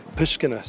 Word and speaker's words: Pushkina: And I want to say it right, the street I mentioned that we're going to be doing our classes there in Uman, Ukraine, is Pushkina Street. There we go, I said it right, thank God.